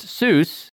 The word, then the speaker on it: Seuss: His most famous pen name is regularly pronounced Seuss, an anglicized pronunciation inconsistent with his German surname, which rhymes with voice.